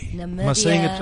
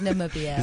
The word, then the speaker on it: Namibia: Namibia. – Am I saying it correctly? – No, it's Namibia. It's okay.